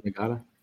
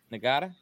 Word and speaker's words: Ngata: Joseph Ngata. – Ngata, yeah.